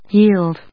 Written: yield

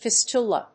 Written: fistula

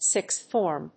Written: sixth form

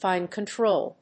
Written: fine control

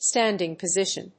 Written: standing position

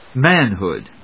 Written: manhood

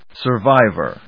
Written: survivor

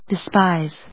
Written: despise

